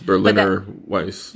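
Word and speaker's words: Berliner Weisse: But also, it's called Athena, but there is a German beer in this, Berlin Weiss. I can't pronounce it. – Yes, Berliner Weiss.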